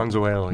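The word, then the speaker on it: Mazuelo: It's a big blend of Tempranillo, Graciano, and Mazzuelo. Is that how you pronounce that? – Mazzuelo, yeah.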